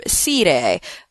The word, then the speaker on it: scire: Its root is the Latin sias, meaning knowing, and that itself comes from the verb sire. That's how I'm going to pronounce it, which means to know. So when you think about that, it's spelled S-C-I-R-E, sire.